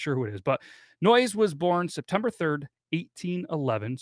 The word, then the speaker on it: Noyes: So it all began with a man named John Humphrey Noyes. N-O-Y-E-S. If you've always been wondering how to say it, it's Noyes.